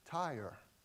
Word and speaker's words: Tyre: The oracle concerning, it's pronounced Tyre, not tear. I was struggling with that. So if I slip and say tear, I apologize in advance, but it's Tyre.